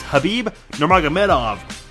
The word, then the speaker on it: Khabib Nurmagomedov: It's Khabib Nurmagomedov.